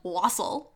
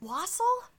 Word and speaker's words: wassail: It's actually full of wassail. – Is it wassail? – Wassail, yeah. – Is it not wassail? – You can pronounce it either way, actually.